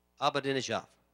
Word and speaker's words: Ahmadinejad: Ahmadinejad.